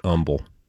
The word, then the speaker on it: Humble: They don't pronounce the H. Humble.